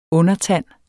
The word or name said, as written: undertand